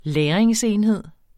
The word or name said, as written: lagringsenheder